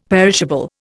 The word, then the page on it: perishable: Indian